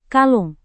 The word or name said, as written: kalung